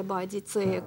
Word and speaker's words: Боадицея: Особо интересно, конечно, потому что восставших возглавила женщина Боудика, как сейчас принято ее произносить, или Боадицея, как писал Тацит.